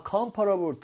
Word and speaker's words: comfortable: Or 중간에 OR 발음을 아예 빼버리는 거예요. Comfortable. Okay.